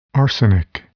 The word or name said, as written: arsenic